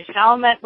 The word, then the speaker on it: Chalmette: Today I'm going down south and talking to Frank Haynes down at Lake Q in, how do I say it, Chalmette, Louisiana. – All right, Chalmette, Louisiana.